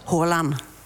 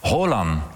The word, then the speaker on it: Haaland: Haaland klinkt als? – Haaland. – Haaland. – Twee A's is een O.